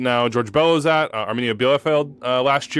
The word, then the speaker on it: Bielefeld: Bielefeld. – Correct. – Bielefeld.